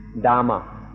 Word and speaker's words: Dhamma: You may have heard the Sanskrit equivalent more often, which is Dharma. But here we use the word in Thai Tama or in a Western pronunciation, Dhamma.